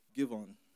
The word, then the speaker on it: Gibeon: Gibeon.